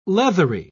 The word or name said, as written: leathery